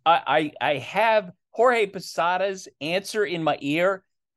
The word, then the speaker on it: Jorge: I like being called Jorge.